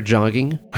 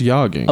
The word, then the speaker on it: jogging: You just kind of naturally like her and her affinity for jogging. I think she must be suicidal. – I believe it's pronounced yogging. – Oh, yogging? – Yeah, it's a soft J.